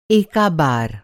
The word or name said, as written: एका